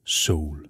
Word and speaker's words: Sol: Men i første omgang kan vi heldigvis nå et stykke musik med hende, som egentlig troede Cleo Sol, men det udtales Soul.